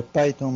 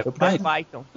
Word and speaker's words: Python: ou Phantom, ninguém sabe como é que pronuncia esse negócio também, né? – É Python mesmo. – É Python.